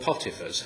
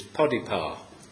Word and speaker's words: Potiphar: in a man called Potiphar's house. Or, as a better pronunciation apparently in Egyptian, is Podipar. Whatever, but he's known as Potiphar.